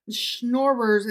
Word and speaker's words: Schnorrers: Schnorrers is spelled S-C-H-N-O-R-R-E-R-S. So not like somebody who's snoring because they're sleeping so hard.